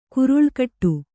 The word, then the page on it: ಕೊರಳುಗಟ್ಟು: koraḷugaṭṭu